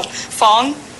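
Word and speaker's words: Phoung: It's spelled P-H. – Sure. – O-U-N-G, but it's pronounced Fong with an F.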